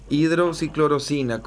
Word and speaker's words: hidroxicloroquina: El fin de semana hablé con una amiga, ella tiene lupus y ella tiene un tratamiento médico hace bastante tiempo utilizando hidroxicloroquina. ¿Así? – Sí. – Así se pronuncia, hidroxicloroquina. ¿Cómo se pronuncia realmente? – Está bien como está pronunciando.